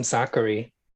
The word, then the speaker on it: Sakkari: We love us some Sakkari. I think. Yeah. Because it rhymes with daiquiri which I love.